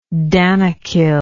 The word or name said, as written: danakil